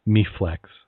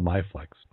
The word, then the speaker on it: MiFlex: Yeah, they're saying uh, MeFlex, MyFlex. I call it MyFlex. That's how they spell it, isn't it? M I F L E X.